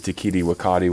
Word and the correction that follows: Taika Waititi: whatever his name. What's his name? – Taika Waititi. – Oh, I like whatever you just said.